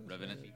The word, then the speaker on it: Revenant: Reverent. The Reverent, if I'm saying that right. – That's Revenant. Right.